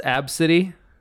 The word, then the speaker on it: ABCDE: Her name is spelled ABCDE. – How is that pronounced? – Apparently it's pronounced Ab City.